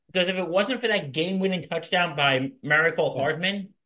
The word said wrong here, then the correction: Mecole Hardman: I said his name right, didn't I? Mecole Hardman. Mecole Hardman.